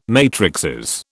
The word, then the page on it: matrices: British English